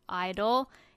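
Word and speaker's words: (G)I-DLE: The ones that were for the lineup was NCT 127, NCT Dream, Stray Kids, Idol, 80s. – Yeah. Silent G. I know. But I still say G Idol.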